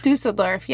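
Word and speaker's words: Düsseldorf: She was sentenced to life imprisonment from the district court of, I'm going to say this wrong, Dusseldorf? – Dusseldorf, yeah.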